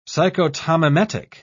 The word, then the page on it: psychotomimetic: British English